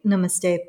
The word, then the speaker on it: namaste: So when we take namaste particularly, in general, it is pronounced with the emphasis on the second syllable. So like na, N-U-H, ma, M-U-H, and then ste, S-T-H-E, but short, like so na, na, ma, ste.